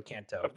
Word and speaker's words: Kanto: Did I say it right? No, Kanto. I said it wrong again.